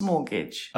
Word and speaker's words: mortgage: Mortgage. – A mortgage. That's it. Not a mortgage, but a mortgage. – Silent T, yeah.